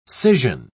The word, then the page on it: scission: British English